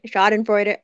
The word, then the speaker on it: Schadenfreude: Okay, so it's not I can't pronounce this in German. Schadenfreude. – Schadenfreude? – Right, Schadenfreude.